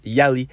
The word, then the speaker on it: Yaleigh: L'artiste d'aujourd'hui, en fait, se nomme Yali, Y-A. L-E-I-G-H, mais ça se prononce Yali.